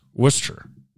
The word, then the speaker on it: Worcester: In New York, if it ends in a S-T-E-R, you have to say it fast. – Worcester. Why are you throwing an O and R? – I'm not. That's how it's pronounced.